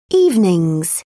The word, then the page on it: evenings: British English